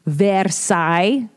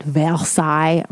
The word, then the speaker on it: Versailles: So I'm currently watching Versailles. That's how they say it. Versailles on Netflix.